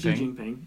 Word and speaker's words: Xi Jinping: Is it Xi Jinping? – Xi Jinping? Xi Jinping.